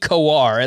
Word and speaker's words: Kowar: It is indeed Kowar, at least the video that I pulled up here. So that is how you pronounce his name, and I agree.